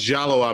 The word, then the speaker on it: Diallo: I was Diallo Amir Riddle, which should have been pronounced Jallo Amir because it's an African name, but it's spelled D-I-A, and they felt like everybody was going to bastardize it and say Jello. So they just said, oh, just call him Diallo.